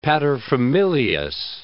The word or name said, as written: paterfamilias